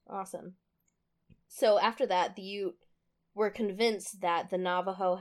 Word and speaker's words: Ute: So the relationship between these two tribes went, I wrote, hella downhill during the Civil War, when the Oot, I really should have looked up how to pronounce this, the Ute tribe allied with a U.S.